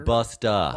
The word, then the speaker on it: Busta: It's Busta. It's even spelled Busta, Marshall. – Did I say? I didn't mean to say. – Hey, Buster. – Buster. – You need to pronounce it correctly. It's Busta.